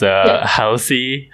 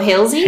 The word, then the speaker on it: Halsey: Second, I don't know how to pronounce this person's name. It's Halsey. Halsey? Halsey. – What? – Halsey. Halsey? – Spell it. – H-A-L-S-E-Y. – Oh, Halsey? – Halsey.